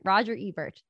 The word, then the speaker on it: Ebert: Roger Ebert.